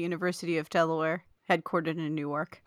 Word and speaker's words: Newark: He said, "We pronounced Newark wrong. ! The city in Delaware is pronounced Newark. So it's Newark, Delaware, which is different. – Newark, Delaware, mm-hmm. which is different from the city in New Jersey.